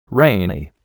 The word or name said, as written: rainy